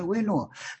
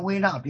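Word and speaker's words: 维那: 对，啊，这个大家念唱的时候领一句，就那个领唱的那个，那就称为维诺。其实他管的事很多，不是光领唱。每一个寺院都有维诺是，这是个支撑。别习惯念成维纳，别那样念。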